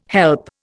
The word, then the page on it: help: Indian